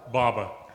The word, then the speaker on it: Baba: Bama? – Baba. B-A-B-A.